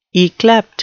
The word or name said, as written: yclept